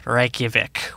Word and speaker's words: Reykjavik: Developed and published by Icelandic developer CCP Games, based out of Reykjavik, which is...